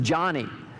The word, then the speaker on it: Joni: His name was John, and that's why her name spelled J-O-N-I is not pronounced Joni, it's pronounced Johnny after her dad.